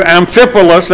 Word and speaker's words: Amphipolis: How do you say that? Boy, I thought I had that. Through Amphipolis, that's it.